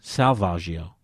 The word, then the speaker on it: Salvaggio: Her last name is says her last name is pronounced like the painter Caravaggio. Have you heard of that painter? – Caravaggio, yeah. – Her name is Salvaggio. – Salvaggio. – Salvaggio. – What's her first name? – Cara. C-A-R.